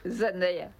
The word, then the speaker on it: Zendaya: ¿Cómo se dice Zendaya? – Zendaya. – Zendaya. – Lo dijo ella.